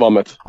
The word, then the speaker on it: Vometh: Of course, it could as well have been that we had like some kind of wordplay in Swedish or something, but like this one, it's just English. It's pronounced Vometh.